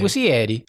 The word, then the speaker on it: Muschietti: I don't know how to say his name. – Muschietti. – I think he's Italian.